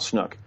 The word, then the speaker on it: Snook: And the other person is a Napa County deputy sheriff named Hal Snook, you could pronounce it Snook. It's spelled S-N-O-O-K. As far as I know, the correct pronunciation is Snook.